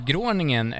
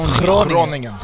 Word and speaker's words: Groningen: Ni som inte har köpt biljett till matchen mot Groningen, Tibbes klubb. – Ja, det där är lite för dåligt uttal. Alltså, Groningen.